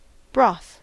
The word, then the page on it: broth: American English